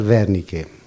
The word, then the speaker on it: Wernicke: Es un apellido de origen alemán, aparentemente, y se debería pronunciar Wernicke.